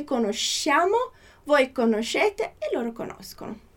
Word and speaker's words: conoscono: Então repara que essa pronúncia mais dura, co, a gente tem só no conosco e no conoscono.